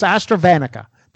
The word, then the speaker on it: AstraZeneca: It's like AstraZeneca. It's not one. It's AstraZeneca. That's how it's pronounced.